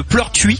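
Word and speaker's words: Pleurtuit: Donc un tracteur est devenu un petit peu fou dans la ville de Pleurtuis.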